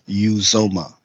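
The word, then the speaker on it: Uzomah: And Uzoma, I heard his name pronounced today. I guess he doesn't, the tight end that they have, he's like, oh, everybody's making up my name. It's Uzoma.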